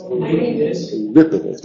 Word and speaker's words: Eurípides: Eurípides, ¿cómo se dice? Eurípides, vale, bien.